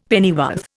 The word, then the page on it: pennyworth: Indian